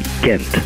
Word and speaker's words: Gant: Die korrekte Aussprache lautet Gant.